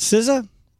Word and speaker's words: SZA: SOS by, is it SZA? – Yes. – Is that how you say it? – Mm-hmm.